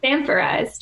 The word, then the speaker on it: Sanforized: How do you say it? Sanforized? – Sanforized, yeah.